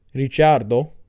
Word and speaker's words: Ricciardo: They did lose Sainz, but they gained Ricciardo, or as his name is actually pronounced, because he's Italian, Ricciardo, but nobody pronounces it like that.